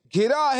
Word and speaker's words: گھرا: گراہے گراہے بلاؤ میں بندہ تمہارا, مدد کے لیے آؤ یا غوث اعظم. – دیکھیں آپ نے الفاظ تو ادا صحیح کیے, تلفظ صحیح نہیں ہے. گھرا ہے بلاؤں میں بندہ تمہارا, مدد کے لیے آؤ یا غوث اعظم.